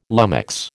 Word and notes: lummox: American English

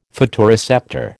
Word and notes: photoreceptor: American English

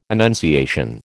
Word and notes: annunciation: American English

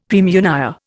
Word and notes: praemunire: Indian

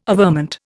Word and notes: averment: Indian

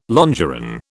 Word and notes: longeron: British English

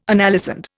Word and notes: analysand: Indian

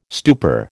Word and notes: stupor: American English

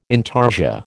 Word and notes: intarsia: American English

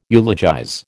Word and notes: eulogize: American English

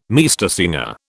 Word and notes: Meistersinger: British English

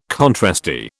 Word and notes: contrasty: British English

